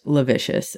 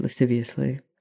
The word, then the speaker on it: lasciviously: I used to think that word was pronounced lavicious, and then I realized that the S and the C and the I come before the B. So I said that wrong for a very long time.